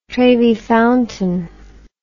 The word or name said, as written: trevi fountain